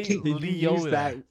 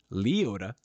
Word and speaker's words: Liotta: The wrong emphasis on the wrong syllables, bro. Ray Liotta.